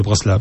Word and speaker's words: Bratslav: De Bratslav, c'est ça, voilà.